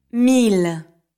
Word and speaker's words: mille: There are a few exceptions when I-L-L or I-L are pronounced like the L in the English word love. – Mille, fil, ville, millionnaire, crocodile.